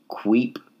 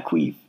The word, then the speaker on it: Quip: I tried the Queep toothbrush. It's like Q U I P.